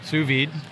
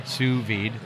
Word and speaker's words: sous vide: Sous vide. – Sous vide. Spell that. – Do you know? It's French. S-O-U-S and vide. V-I-D-E. Sous vide.